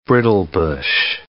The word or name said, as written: brittlebush